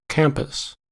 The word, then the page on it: campus: American English